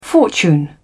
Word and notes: fortune: British English

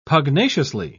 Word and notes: pugnaciously: British English

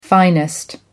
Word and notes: finest: American English

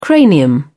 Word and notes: cranium: British English

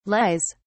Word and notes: lies: British English

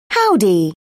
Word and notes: howdy: British English

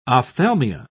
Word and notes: ophthalmia: British English

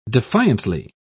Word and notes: defiantly: British English

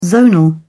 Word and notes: zonal: British English

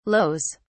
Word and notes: lows: British English

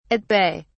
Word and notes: at bay: British English

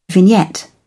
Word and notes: vignette: British English

